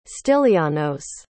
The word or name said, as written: stylianos